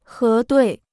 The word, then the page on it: 核对: hé duì